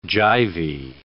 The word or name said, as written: jivey